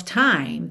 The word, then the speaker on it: time: E the end of time. The end of time. Time, lembra de terminar no M, ok? Esse E não é pronunciado.